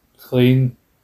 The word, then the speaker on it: Clayne: Clean, clean, Clayton, clean, clean. Like it's spelled C, uh, C L A Y N E. Clean, mm-hmm.